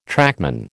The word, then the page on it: trackman: American English